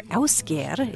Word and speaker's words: Ásgeir: This is the Icelandic singer Ásgeir. It's spelled A-S-G-E-I-R.